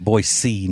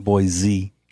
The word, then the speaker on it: Boise: You know, that's how you say it. Boise, not Boise.